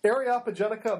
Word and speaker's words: Areopagitica: So I figure I need to have you talk about Milton's anti-censorship treatise, Areopagitica.